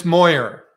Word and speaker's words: Moyer: It's Moyer.